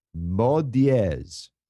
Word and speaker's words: mot dièse: The new Twitter term is pronounce mot dièse.